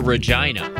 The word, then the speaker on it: Regina: But there's a town in Canada that's spelled R-E-G-I-N-A. So it's Regina.